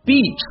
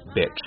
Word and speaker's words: beach: like hit hit，还有一对词 beach bitch。几个朋友去海滩玩，感慨一声哈， This is a beautiful beach。 – 千万千万别读错。